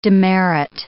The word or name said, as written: demerit